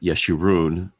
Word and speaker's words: Jeshurun: I don't think that we talked about this in the last episode, but the name Jeshurun, that's J-E-S-H-U-R-U-N, which in Hebrew would be pronounced like Yeshurun, but Jeshurun is a name for Israel that doesn't come up very often.